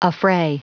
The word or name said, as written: affray